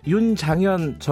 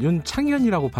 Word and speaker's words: 윤장현: 제가 아까 윤장현 전 광주시장을 윤창현이라고 발음을 한 모양이네요. 죄송합니다.